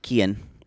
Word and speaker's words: Cayn: You love saying his name, Kian, don't you? – It is. – Is he cute? – I don't know. – You need to see what he looks like. – It's spelled C-A-Y-N, Kian.